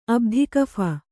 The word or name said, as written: ಅಬ್ಧಿಕಫ